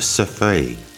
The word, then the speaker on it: Cerfeuil: But in French, it's pronounced Cerfeuil and spelled C-E-R-F-E-U-I-L.